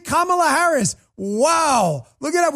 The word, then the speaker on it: Kamala: Don't mispronounce it. Jim Acosta will definitely get on you. He says Kamala Harris. Kamala, because you call her Kamala, even though they called her Kamala before.